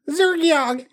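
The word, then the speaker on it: Xergiok: Is that how you say his name? – Zergiok. Yep.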